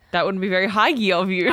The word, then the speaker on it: hygge: Huggy? – Huggy? – Hi, Gee. Who go? – Pardon? – I read somewhere that it's actually pronounced like huggo. – It might be. But, okay, so the word is H-Y-G-G-E. – H-Y-G-G-E.